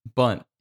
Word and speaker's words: Bunt: Okay, because I thought his name was Bud, but okay, Bunt. I was like, what is his name? Because I heard it pronounced Bunt, but I also heard it sound like Bud sometimes.